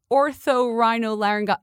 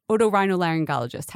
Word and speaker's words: otorhinolaryngologist: Oh, this is from the orthorhinolaryngologist. Oh, it's like a, you know, what are they called? Otorhinolaryngologist.